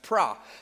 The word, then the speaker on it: pro: This particular word gnosko has a prefix in front of it, which is pro, P-R-O, which actually pronounced pra.